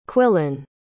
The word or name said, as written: quillon